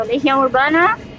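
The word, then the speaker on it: Legião Urbana: Vamos a ir al Brasil de los de inicios de los 80 para hablar de la primera banda, de Legión Urbana. No sé si lo he pronunciado bien. – Eh, pronunciaste perfecto, Legión Urbana.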